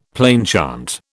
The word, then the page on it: plainchant: British English